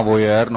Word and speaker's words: voyeur: Já teď nevím, jak se to správně vyslovuje, někdo tomu říká voyeur, někdo tomu říká, asi to je voyeur, no, možná, nebo voyager, nebo... – No voyager, to je to nové auto, ale myslíš téma voyeur, no tak znal jsem jednoho zahrádkaře,